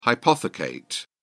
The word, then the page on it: hypothecate: British English